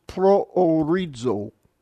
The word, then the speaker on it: proorizo: The word for predestination is proorizo. For those of you that don't know Greek yet, these two letters that look like P are R's. P-R-O-O-R-I-Z-O. Pretty close. Proorizo. So both the omicrons are pronounced separately.